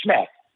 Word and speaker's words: SHMEC: I call it SHMEC. S-H-M-E-C. It's an acronym pronounced SHMEC.